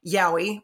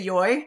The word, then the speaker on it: yaoi: which I hate that pronunciation. It drives me crazy. I always say yoi. I've always grown up saying yoi. Like if you hear a Japanese person say it, I feel like it sounds somewhere in between the two.